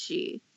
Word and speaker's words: Sidhe: The She, which is actually spelled S-I-D-H-E, but it's pronounced She, is